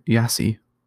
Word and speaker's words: YaCy: Today we're talking about an alternative search engine called YaCy, or at least that's how I pronounce it Y A C Y.